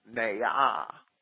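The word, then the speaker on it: na'ah: These words are both pronounced ne'ah, N-A apostrophe A-H.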